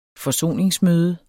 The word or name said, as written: FORSONER